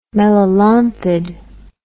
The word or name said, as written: melolonthid